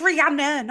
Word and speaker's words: Rihanna: It's Rihanna, not Anna.